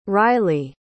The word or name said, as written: ryleigh